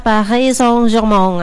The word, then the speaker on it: Paris Saint-Germain: Sebenarnya baca PSG ini enggak, bukan Paris Saint-Germain ya. Kalau Mbak Anggun Cisasmi tahu, kita bacanya kayak gitu. Dimarahin nih. Sebenarnya bacanya itu agak sengau-sengau orang Prancis ya, Paris Saint-Germain alias PSG bersama RC Lens.